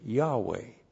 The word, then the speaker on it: Yahweh: This word was so holy in the eyes of Orthodox Jews, so holy, they wouldn't even pronounce it, they wouldn't even say it. If you said this word, it would be Yahweh.